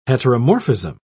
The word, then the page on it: heteromorphism: British English